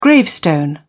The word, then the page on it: gravestone: British English